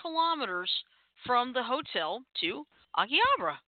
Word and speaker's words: Akihabara: Akihabara. – Okay. Akihabara.